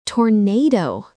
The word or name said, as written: tornado